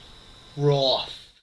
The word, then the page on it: wroth: American English